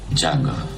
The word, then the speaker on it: Django: Django. – Sai come si scrive? – D-J-A-N-G-O.